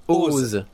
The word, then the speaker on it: OOO: La série Oz, donc ça, on met O-O-O, voilà, ça se prononce Oz. – C'est pas triple O, c'est Oz.